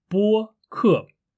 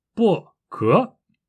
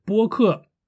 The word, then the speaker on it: 播客: 用相反的声调来说话，比如“ 播客” 这个词，它的发音应该是“ 播客”，一声和四声。我把这个发音交给了你，但是你非要把这个发音说成是“ 播可” 或者是“ 播可”。嗯，我告诉了你，它的发音是“ 播客”，但是你一定要说成是。